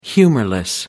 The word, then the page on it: humorless: British English